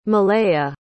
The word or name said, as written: malaya